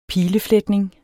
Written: pilefletning

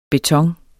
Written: beton

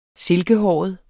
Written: silkehåret

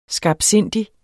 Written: skarpsindig

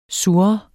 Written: sur

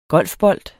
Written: golfbold

